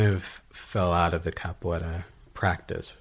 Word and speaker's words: capoeira: I was wondering how to pronounce that. – Yeah. – It's capoeira. – Capoeira. – Okay.